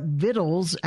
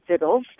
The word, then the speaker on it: victuals: First, we just wanted to know what vittles actually means. – It is an English word, and it was always phonetically pronounced vittles. That was the correct way to say the word. V-I-C-T-U-A-L-S is to make the word look a little bit fancier.